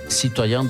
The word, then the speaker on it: citoyen: Citoyen do mundo, falei certo, Bernardo? Não, falei errado. Certíssimo, tá certo tempo.